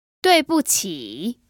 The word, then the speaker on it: 对不起: 对不起。 – So it's actually three characters. The first two are fourth tones, and the last character is a third tone.